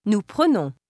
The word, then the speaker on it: prenons: And since the plural endings all begin with a vowel, the end sound of the stem is pronounced. – Nous prenons.